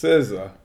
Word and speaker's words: SZA: SZA. I don't know how people can't get her name right. It's like RZA, only with the S. – SZA.